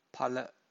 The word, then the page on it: phala: Pali